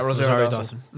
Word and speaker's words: Rosario Dawson: The fact that they got Rosario Dawson, that's how you say her name, right? – Yeah, Rosario Dawson.